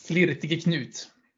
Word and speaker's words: Flirtige Knut: Jag måste uttala det rätt nu för det var så han själv What? Flir, ja. Flirtig Knut? – Flirtige Knut.